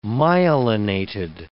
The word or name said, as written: myelinated